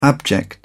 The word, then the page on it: abject: British English